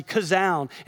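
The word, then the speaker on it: Kazown (חזון): The word for vision there is chazam. Chazown with a hard C, Kazown.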